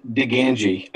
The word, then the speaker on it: Digangi: Our last name is Diganji. I don't feel it's very hard to pronounce. – Diganji. – D-I-G-A-N-G-I. Diganji. Pretty simple. It's Italian.